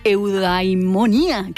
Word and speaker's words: eudaimonía: De hecho, hay una relación íntima entre filia, que proviene del griego filia y significa amistad, y eudaimonía, que es felicidad.